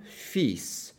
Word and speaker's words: fils: So the spelling of the word for son is F-I-L-S, and it is pronounced le fils. So the rule is that you completely ignore the L and pronounce the S as if it were S as in Sam.